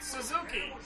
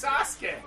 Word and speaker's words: Sasuke: It's Great Suzuki. No, it's Sasuke. Not Suzuki.